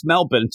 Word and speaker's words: Melbourne: But yeah, then we go to Melbourne, Australia, Eric, as we say. It's pronounced Melbourne. So you end up with Wingman. You're saying it wrong.